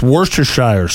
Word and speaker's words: Worcestershire: I think everyone on the planet struggles to say Worcestershire sauce. Did I say that right? – No, you didn't. No. You added like an extra "er" in there. – Hmm. – It's just Worcestershire sauce.